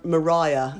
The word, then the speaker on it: Maria: As you say, it's an old English way of pronouncing it. Can you explain why you say Mariah? – Well, nobody knows, obviously, because there's no recordings, but Anne Dinsdale at the parsonage feels it most likely was Mariah. That's an older pronunciation.